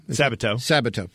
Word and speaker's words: Sabato: Sabato.